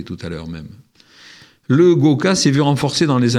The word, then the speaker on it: gwoka: Mais on occulte trop souvent la culture guoka. Ça s'écrit G-W-O-K-A.